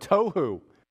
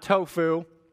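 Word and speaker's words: tohu (תֹהוּ): This word without form, we'll see it in a minute, but it's the word tohu, not tofu. Tohu. Void is only used, I think, let me make sure I say this correctly.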